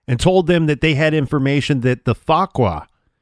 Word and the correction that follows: Fuqra: And I'm not saying that right. F-U-Q-R-A, Fakra.